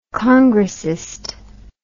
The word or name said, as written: congressist